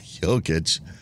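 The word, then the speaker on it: Jokić: Jokic? Is that how you say his name? – Jokic. Nikola Jokic.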